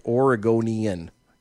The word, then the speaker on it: Oregonian: According to the Oregonian, I said Aragorn. – That is really not a good word to be saying. – I know. According to the Aragorn. – Okay. – Aragonian. Let me say it right. Aragonian.